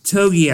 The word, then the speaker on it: Togiai: Tommy, Togiai. I think I said that right. – Yep.